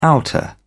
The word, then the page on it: outer: British English